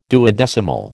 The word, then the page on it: duodecimal: American English